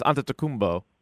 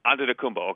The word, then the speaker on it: Antetokounmpo: Sorry, that's just the Greek pronunciation. Giannis Antetokounmpo. – Antetokounmpo. Okay.